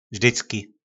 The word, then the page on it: vzdycki: po našimu